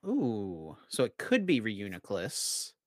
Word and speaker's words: Reuniclus: It's Reuniclus. – Of course, yeah. Reuniclus.